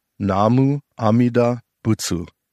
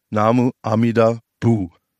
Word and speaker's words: Namu Amida Butsu: Um das von der Aussprache her besser wiederholen zu können, sagen wir Namu Amida Bu.